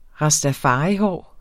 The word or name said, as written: rastafarihår